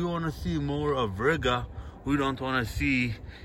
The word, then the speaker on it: Riga: So I'm out here, guys, in Riga, which rhymes with nigga.